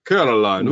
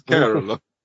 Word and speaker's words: Coraline: I do love how everybody mispronounces her name all the time. Like, it's pretty good. – My favorite character, the Russian acrobat mouse guy, and he kept Coraline. Yeah. Who calls who? It's Coraline.